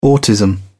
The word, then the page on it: autism: British English